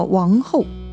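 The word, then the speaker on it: 王垕: 这个时候，曹操手下管理粮食的官员名叫王后，这个后呢，就是后来的后，下面再加一个土字啊。也念成后，这个王后啊就来报告曹操了，说呀：“ 丞相啊，兵多粮少，该咋办呢？” 曹操就说了：“ 用小壶分粮，权且救一时之急。” – 王后觉得这个似乎行不通啊，小壶分粮，大家吃不饱，那不是更加埋怨吗？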